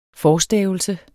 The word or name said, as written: forstavelse